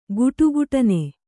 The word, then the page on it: ಖಡಖಡನೆ: kannada